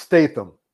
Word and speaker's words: Statham: And it's pronounced Statham.